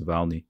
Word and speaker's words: Navalny: Like currently, I'm my priority, at least at the moment, is to do a short documentary on the poisoning of Alexei Navalny, or Navalny. I always forget how it is that you pronounce his last name. I believe it's Navalny, at least in the American accent. It is.